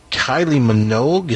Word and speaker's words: Minogue: It's been recorded by Taylor Swift, Natalie Merchant, Kylie Minogue.